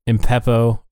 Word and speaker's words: Impepho: Go follow Nathan's jewelry page on Instagram, Impepo.